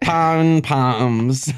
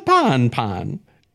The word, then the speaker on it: pom-pom: Pom-poms. How do you say it? – It's with an M. – Is there a pon-pon? – It's not a pon-pon. – Pom-pom.